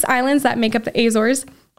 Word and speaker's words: Azores: So I was on the Azores, or the Azores, that's how you say it in English.